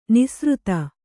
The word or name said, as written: ನಿಸೃತ